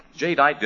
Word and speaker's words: Jadeite: Now, it's spelled J-A-D-E-I-T-E, Jadeite.